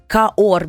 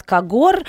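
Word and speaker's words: Cahors: Так, более трех столетий назад духовенство выбрало для для религиозных обрядов насыщенный, терпкий и совсем не сладкий напиток каор без буквы Г, поскольку во французском языке пишется кахорс, получается. Вот. Э, но э, H, э, ха, не читается. По незнанию французское слово каор стали произносить на свой лад кагор, как часто бывает, кстати.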